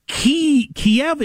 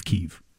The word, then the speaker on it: Kyiv: Kiev is the way Ukrainians say it. I want to be on the side of the locals, not the Russia says it belongs to us, we'll tell them how to pronounce it. I'm on the side of the Ukrainians, so I'm with Kyiv.